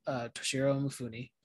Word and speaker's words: Toshirô Mifune: So we're going to be watching 1957 Throne of Blood by Akira Kurosawa, starring the amazing Toshirô Mifune.